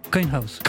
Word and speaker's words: Coinhouse: On prononce ça comme ça? – Coinhouse. – Coinhouse. – À l'américaine. – Coinhouse, comme une pièce.